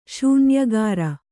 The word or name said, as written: ಶೂನ್ಯಗಾರ